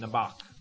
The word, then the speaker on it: Nebaj: N-E-B-A-J. It's pronounced Nebach.